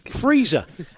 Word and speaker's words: Frieser: F-R-I-E-S-E-R, pronounced Freezer.